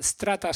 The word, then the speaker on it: STR4TA: el cual se llama Strata STR4TA, Strata se pronuncia,